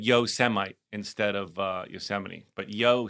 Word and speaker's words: Yosemite: And he pronounced Yosemite, he was reading a speech off a prompter that I'm sure he had never looked at, as Yosemite.